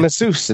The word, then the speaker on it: Asus: I actually used to pronounce it Asus, but yeah, it's just they pronounce Asus. Yeah, I used to too. I remember because it was on this show, actually, when I first said it out loud, and you're like, no, it's Asus. And I asked someone from Asus, and they're like, yeah, it's Asus.